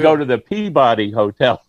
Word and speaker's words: Peabody: Bill, since you're north of Boston, how do you pronounce P-E-A-B-O-D-Y? – Peabody.